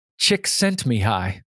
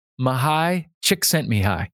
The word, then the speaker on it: Csikszentmihalyi: It's pronounced Csikszentmihalyi. Mihály Csikszentmihalyi. Now, here's a silly way you can remember it.